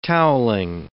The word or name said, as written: towelling